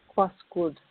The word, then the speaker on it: Cuascud: And we're speaking with Dr. Tanginika, say your last name, Cuascud? – Cuascud.